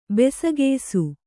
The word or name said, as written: ಬೆಸಕೆಯ್ಸು